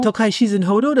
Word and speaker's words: Tokai Shizen Hodo: Den, wie wird der erste ausgesprochen? Tokai Shizen Hodo? – Tokai Shizen Hodo, das war die erste Idee.